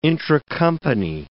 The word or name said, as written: intracompany